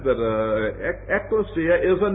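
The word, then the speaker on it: ekklesia (ἐκκλησία): But Harold Greenlee tells me that the word church is a Greek word that is pronounced something like this, ekklesia, from which we get our word ecclesiastical and ecclesiasticism.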